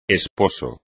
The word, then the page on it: esposo: espagnol